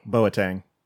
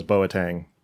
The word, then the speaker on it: Boateng: Yeah, like I said, all I know is there's a soccer player whose last name is Boateng, and it's spelled the same.